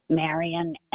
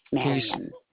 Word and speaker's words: Marian: LE capital PINO, and it's Marion MARIAN. – So I take it Lupino is your last name? – Yes. – You said Miriam. – With an N like Nancy. Marion.